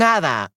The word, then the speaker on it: hada: Um, un hada, un hada. Why? Because the H is silent. So remember that in Spanish, the H is kind of like it doesn't exist.